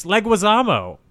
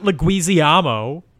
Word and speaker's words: Leguizamo: Yeah, that's still how it's pronounced. It's Leguizamo. Not Leguizamo.